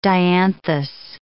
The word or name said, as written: dianthus